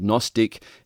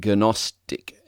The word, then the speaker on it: Gnostic: And that word Gnostic, as I mentioned in the first audio, has a silent G in front of it. So if we were to pronounce the G, we would be saying Gnostic.